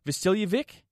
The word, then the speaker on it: Vasiljevic: Dion, how are you going to pronounce it? Because there's two ways that people pronounce it. Vasiljevic? Is that how what we're going with? – Yeah, that's what we're going with.